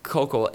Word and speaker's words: Koukl: His last name, I think, is spelled K-O-U-K-L. So Kokel, and it was so good because he uses this tactic called the Columbo tactic.